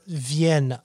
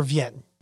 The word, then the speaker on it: Vienne: a tributary breaks off called the River Vienne. I believe it's pronounced that way, V-I-E-N-N-E, very similar to the town in the southern Rhone we talked about, but no connection really.